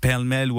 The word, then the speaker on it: pêle-mêle: Et bravo pour la prononciation de pêle-mêle. – Pêle-mêle. – Franchement, ça le fait. Ben, j'ai eu des palais malais, j'ai tout eu, mais toi, t'as fait ça très bien.